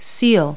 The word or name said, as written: seal